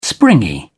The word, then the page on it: springy: British English